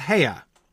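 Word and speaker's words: Haigha: His name is Haya." He pronounced it so as to rhyme with mayor, though I believe to rhyme with how a British person would say mayor, which is without the R that we would give it here in America.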